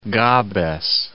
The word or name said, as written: Gabes